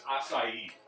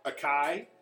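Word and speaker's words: açaí: I think it's acai. – You got the lager. That's acai. Acai. That's how you say it. – Akai.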